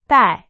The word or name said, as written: 戴